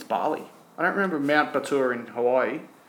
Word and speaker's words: Batur: The way I pronounce that might make it hard. It's Batur, B-A-T-U-R.